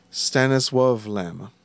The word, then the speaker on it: Stanisław Lem: Stanisław Lem, actually, he's Polish, so I guess it would be pronounced Stanisław Lem.